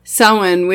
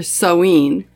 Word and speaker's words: Samhain: In the Northern Hemisphere, Samhain, which can be pronounced as I did, or Samhain, depending on whether you're coming from the Scottish or Irish Gaelic, is usually celebrated on October 31st and therefore corresponds to Halloween, although some traditions put Samhain on November 7th.